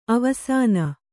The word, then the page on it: ಅವಸಾನ: kannada